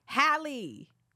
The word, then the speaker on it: Halle: Halle. You don't know Halle Berry, the song? That's how you pronounce it.